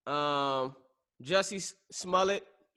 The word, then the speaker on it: Jussie Smollett: Me, Jussie Smollett. How you pronounce the name? – That works.